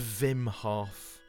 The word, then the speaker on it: Wim: How do I pronounce it? Wim or Vim? What do you want? – I think it's Wim Hof.